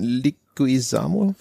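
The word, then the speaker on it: Leguizamo: Der in äh, Luigi spielt John Liguizamo. Wie spricht man dessen Nachname aus? – Weißt ja, das, das war schon richtig.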